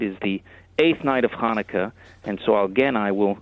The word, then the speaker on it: Hanukkah: Most people say Hanukkah, but the way you pronounce it is Hanukkah.